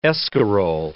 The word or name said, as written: escarole